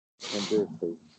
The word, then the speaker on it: Humberto: So I hope the guy is not Cedric Alexander, but it's looking like it'll either be Cedric or Humberto, if I said that right.